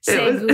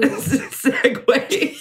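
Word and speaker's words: segue: And I was like, there's this one word I can never remember, but I always said it wrong. Segu. – Segway.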